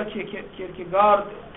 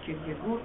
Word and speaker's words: کیرکگور: در باب این که کیرکگور یا کیرکگارد تلفظ باید بشه، اختلافی در تلفظ دانمارکی و غیر دانمارکیش هست. دارن تلفظ های غیر دانمارکیش کیرکگارد، دانمارکیش کیرکگور هست.